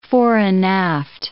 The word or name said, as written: fore-and-aft